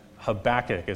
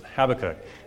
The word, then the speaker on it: Habakkuk: Then after lunch we're going to look at the Song of Deborah in Judges 5, and then we'll look at the Song of Habakkuk, is how Americans pronounce it, Habakkuk, tomorrow morning.